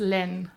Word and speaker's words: LÆN: How do we pronounce it right? – It's Len.